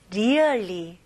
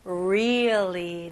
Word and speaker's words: really: 근데 발음을 really, really? 이렇게 하시면 안 됩니다. 네, 첫 번째. R 발음은 일단은 천장을 닿으면 안 되고 혀가 뒤로 가는 느낌이 나야 됩니다. 그래서 really, 네, 되게 버터 발음이죠. really, really, okay? 그리고 여기에 E 발음은 조금 더 길게. 그래서 really 이게 아니고 really, really, 네, 뭔가 고무줄 당기는 느낌입니다.